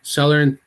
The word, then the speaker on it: Salernitana: I think I'll take them out and I'll put – I always pronounce this word, this team, the worst, but like Salernitana.